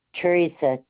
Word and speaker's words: Teresa: Teresa, T-E-R-E-S-A.